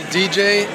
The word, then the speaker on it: djay: It's called DJ. It's spelled DJAY.